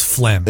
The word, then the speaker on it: phlegm: Yeah, that silent G really kind of ruins phlegm.